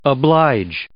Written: oblige

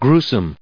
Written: gruesome

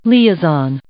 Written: liaison

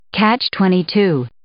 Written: catch-22

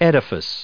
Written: edifice

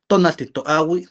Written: tonalti totlayi

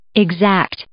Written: exact

